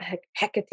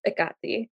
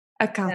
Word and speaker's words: Hecate: My roommate is Greek, and her mother kind of just explained to us, she was like, it's not Hecate, it's not Hecate, it's Akati. – Akati.